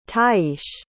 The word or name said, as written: tyeis